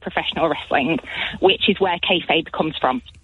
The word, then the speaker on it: kayfabe: I wanted to give you a call to have a chat about kayfabe. – Marvellous. And that is the correct way to pronounce it.